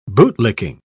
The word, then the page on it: bootlicking: British English